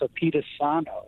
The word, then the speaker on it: Apatizano: Rob actually gave me some interesting way to remember how to pronounce that, and it's Apatizano. It's kind of like you're speaking of a pizza or something like that.